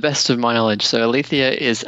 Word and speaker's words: aletheia: that is the proper pronunciation, Aletheia.